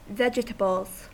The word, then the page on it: vegetables: American English